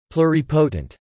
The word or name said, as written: pluripotent